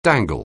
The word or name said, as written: dangle